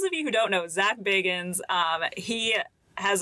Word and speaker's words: Zach Bagans: Yes, Zach Bagans.